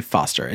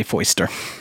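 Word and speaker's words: Foyster: I'm going to say Foster. It's F-O-Y-S-T-E-R-S. It could be Foister, but that's a weird... I don't like saying that. – It just sounds like a bad pronunciation of Foster. – Exactly. – But I want to say Foister. – Okay, we'll say Foister.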